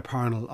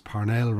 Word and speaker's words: Parnell: Are we all pronouncing it incorrectly? – Well, in Ireland, I suppose he's always been known as Parnell rather than Parnell.